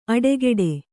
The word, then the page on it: ಅಡೆಗೆಡೆ: aḍegeḍe